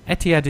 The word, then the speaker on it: Etihad: Etihad? – No, Etihad is fine.